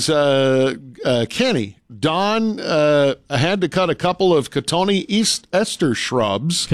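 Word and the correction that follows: Cotoneaster: Cotone Ester? – Yeah. – Yeah, it's spelled like cotton Easter, but yeah. – Okay. – Cotone Ester.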